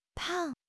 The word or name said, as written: pang4